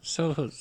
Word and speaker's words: Sohus: And then they discover a corpse that is thought to be the Soho, one of the Soho's, I think it's S-O-H-U-S. How would you say that? Soho's? – S-O-H-U-S. – So's? – So's.